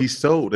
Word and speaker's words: Tissot: Tissot, the T is silent. – The second one, yes.